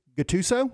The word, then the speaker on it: Gattuso: Um, so the, the head coach, Greg Gattuso, do you Gattuso.